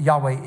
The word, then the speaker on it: Yahweh: So his name is Yahweh, and Yahweh is the best pronunciation of the way the name of God is to be communicated.